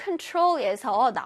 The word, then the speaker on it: control: Remote, remote, control는 끝에 TROL에 강세가 들어가요.